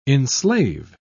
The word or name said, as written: enslave